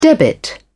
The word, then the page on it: debit: British English